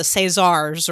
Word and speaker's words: César: But the Caesars or the Césars or the – help me out. You can pronounce French things. – César? Yeah, that's – You think so? – César? Okay. I don't know.